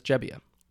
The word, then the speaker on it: Gebbia: But he somewhat recently, within the last couple of years, did like a family genealogy project found out where his family was from and found out that they actually say Gebbia there. – Oh, that's interesting. – So he has actually changed how he pronounces his last name. And so now his last name is Gebbia.